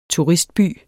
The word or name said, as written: turistby